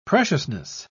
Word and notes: preciousness: British English

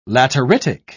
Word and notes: lateritic: British English

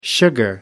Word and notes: sugar: American English

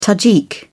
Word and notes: tajik: British English

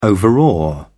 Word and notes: overawe: British English

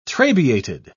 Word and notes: trabeated: British English

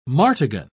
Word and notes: martagon: British English

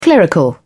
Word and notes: clerical: British English

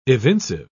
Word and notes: evincive: British English

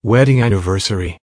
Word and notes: wedding anniversary: American English

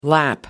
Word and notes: lap: American English

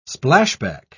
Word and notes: splashback: British English

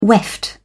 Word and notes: weft: British English

